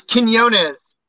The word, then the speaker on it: Quinonez: How do you say your last name? – Yeah, it's Quinones.